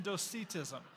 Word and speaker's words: Docetism: The G is silent in Gnosticism, and the C's sound like S's because English is dumb. But Gnosticism and Docetism.